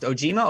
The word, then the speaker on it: Ogema: At the time, however, at his home in the town of, I believe it's pronounced Ojima, O-G-E-M-A, he had speeds not much better than a dial-up modem from the 1990s.